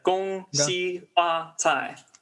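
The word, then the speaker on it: Gongxi Fa Cai (恭喜发财): So I'm going to mess up this pronunciation. Gongxi Fai Fasai? – It's a Gongxi Fa Cai.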